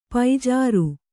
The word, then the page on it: ಪೂಜಾರಿ: pūjāri